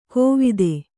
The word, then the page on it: ಕೋವಿದೆ: kōvide